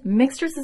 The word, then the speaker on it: Mixtress: And again, mixtress is with an X. A lot of people like think I'm just mispronouncing mistress, but it's mix because music, mixtape, Mixtress.